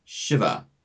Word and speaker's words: Shiva: When you say the name of this deity in Sanskrit, the name is Shiva, with a short I, Shiva.